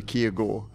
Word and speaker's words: Kirkegaard: You'd think it with a name like Kirkegaard, I would have this down. But as I like to say, when I was in Copenhagen, I couldn't even pronounce my own surname, which is apparently Kiergaard.